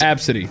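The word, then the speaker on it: Abcde: How do you pronounce it? – It's Absidy. – Absidy.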